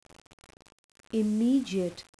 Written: immediate

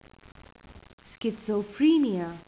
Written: schizophrenia

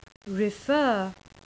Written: refer

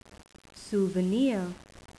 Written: souvenir

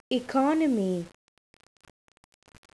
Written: economy

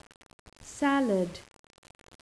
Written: salad

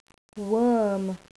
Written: worm

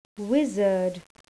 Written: wizard

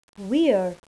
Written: we're

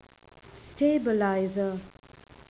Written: stabilizer

